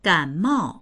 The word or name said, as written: gan3mao4